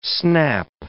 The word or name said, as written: snap